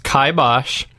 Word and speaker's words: kibosh: See, I think it is kibosh. I think my neighbor's right. – Pronounced kibosh.